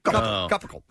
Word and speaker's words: Cupacle: It's spelled C-U-P-A-C-L-E. Capackle.